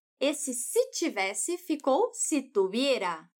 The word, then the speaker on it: tuviera: Esse verbo conjugado assim, tuviera, se escreve com V. E a Juana fala esse V com o som de B do espanhol. Aquele mais sutil, porque a letra V está entre vogais.